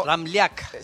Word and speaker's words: Ramljak: La J tiene pronunciación de I, en realidad. Siempre es croata. Y es como... – Ramljak. – Sería Ramljak.